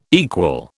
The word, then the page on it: equal: British English